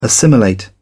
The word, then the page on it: assimilate: British English